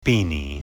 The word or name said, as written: beanie